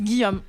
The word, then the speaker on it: Gijom: Alors, ça s'écrit G-I-J-O-M. – Gijome. – Oui, c'est exactement ce que me dit Léo. Ça se prononce Guillaume en espéranto.